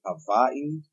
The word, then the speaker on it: Hawaii: And I think they pronounce it Hawaii. – Hawaii. Yeah, there's a weird little accent.